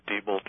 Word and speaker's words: Diebold: How do you pronounce it? I've always said Diebold, but no, it's Diebold. It's Diebold, actually.